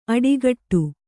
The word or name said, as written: ಅಡಿಗಟ್ಟು